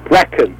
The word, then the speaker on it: Brecon: in the, it's pronounced Brecon, is that the correct pronunciation? – Brecon. – Brecon, okay.